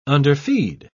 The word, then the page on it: underfeed: British English